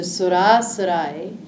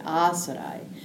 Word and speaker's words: Surasurai: So Surasurai is Sura and Asura, right? And so when you put them together, it makes a long A, Asurai.